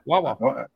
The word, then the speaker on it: Wawa: At least you know how to pronounce it. – Yeah, Wawa.